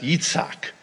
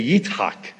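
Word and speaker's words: Yitzhak (יצחק): Isaac in Hebrew is pronounced Yitzhak. It's the same word in my native tongue of Arabic. We say Yitzhak.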